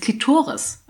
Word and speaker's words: Klitoris: Das heißt Klitoris. Ich so, willst du mich verarschen? Das heißt Klitoris. Also die Betonung mehr auf dem O.